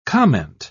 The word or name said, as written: comment